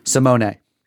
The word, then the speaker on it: Simone: So that's how you say his first name, which is spelled Simone. – Simone.